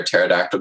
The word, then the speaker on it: pterodactyl: I mean, my best cringy joke is probably, you've probably heard it, but why can't you hear a pterodactyl go to the bathroom? – I have never heard this. – I don't know. – Because the P is silent.